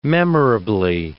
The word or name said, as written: memorably